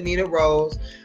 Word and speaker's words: Nina Ross: Well, my name is Nina Ross, like he said. Some people, we pronounce it Nina Rose.